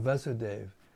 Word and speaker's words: Vasudeva: When Krishna appeared as the son of Vasudeva in Devaki, he first of all appeared. Well, that's a mistake. There's no long A in Vasudeva. Make a note. Okay? When Krishna appeared as the son of Vasudeva in Devaki, he first of all appeared as four-armed Narayana.